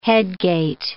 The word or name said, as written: headgate